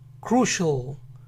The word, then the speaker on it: crucial: Para crucial, recuerden que tienen que pronunciar la R como R. Primero practiquen pronunciar las dos primeras consonantes, K y R. Cr, cr, cr. Ahora añadan U. Crew, crew, crew. Entonces, crucial, crucial, crucial.